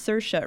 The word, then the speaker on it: Saoirse: It's Saoirse. It rhymes with inertia.